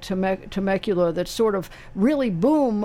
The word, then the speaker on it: Temecula: And then at that point, Is it pronounced Temecula? – Temecula.